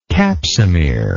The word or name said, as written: capsomere